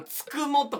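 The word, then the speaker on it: 九十九: でもつくもとかありますね。 99 と書いて。 – つくもはなぜ？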